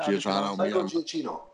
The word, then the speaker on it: Giacchino: Giacchino or something? Is it? – Giacchino. – Michael Giacchino. – Okay. – Trust me, I get it wrong. – The Italian will tell you how to say it properly.